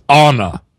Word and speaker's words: Anna: Oh, actually, actually, I was corrected in the pronunciation of that by my, by my uh, loquacious daughter, who said, it's Anna.